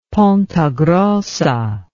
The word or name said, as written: ponta grossa